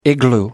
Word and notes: igloo: American English

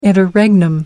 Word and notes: interregnum: American English

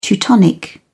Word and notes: teutonic: British English

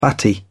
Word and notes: batty: British English

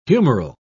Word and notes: humoral: British English

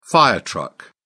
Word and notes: fire truck: British English

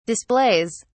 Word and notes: displays: British English

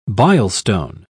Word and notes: bilestone: British English

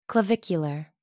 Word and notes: clavicular: American English

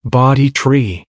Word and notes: bodhi tree: American English

